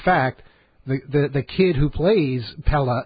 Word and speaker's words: Pelle: Pell the Conqueror. Pell Aerobrerin. – I think you pronounce it Pella. – Pella. – No, it's not Pella. Pella, I think it is. – Pella the Conqueror. – Pella the Conqueror. It's okay. Spelled P-E-L-L-E,